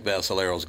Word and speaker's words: Vassalero: We got to call in because in the what you just said, Frank Vassalero's got to do something. He's got to shorten that name up quite a bit. – But you just said it properly. I mean, Vassalero, yeah, but I grew up with Italians and Sicilians, so that's why.